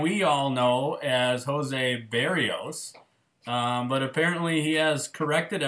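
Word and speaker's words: Berríos: So when I was listening to the broadcast, Dan was saying Berrios, and then he went to this explanation that Berrios had told everyone that that's how you say it and that's how you go to it.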